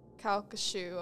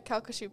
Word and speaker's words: Calcasieu: But yeah, the visitors and the employees working on the Calcasieu, oh, I said it right, Calcasieu Parish, are convinced that Henry's spirit is still haunting it.